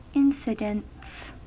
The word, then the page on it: incidents: American English